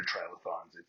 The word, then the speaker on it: trilithon: And as far as I can tell, in English, it's pronounced trilithon or trilith. But to a person in marketing and media, it's always referred to as Trillith.